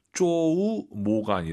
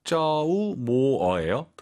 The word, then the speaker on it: 周末: 주말 발음을 쪼우 모가 아니라 쩌우 모어예요.